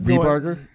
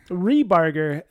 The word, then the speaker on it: Ripperger: Yeah, I thought about Rehbarger. Rehbarger. That's how we pronounce it. Is Rehbarger. – Yeah. – Even though it's spelled R I P P E R G E R.